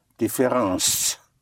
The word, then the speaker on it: différence: Both ENCE and ANCE in French are pronounced ans. Like difference would be différence.